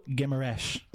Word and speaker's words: Guimarães: Bruno Guimarães. Is that good enough? – That's pretty good.